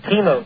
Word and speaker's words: Timo: This is Timo from Sacramento. – You say your name is Chemo? – No, Timo. T-I-M-O.